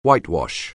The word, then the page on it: whitewash: anglais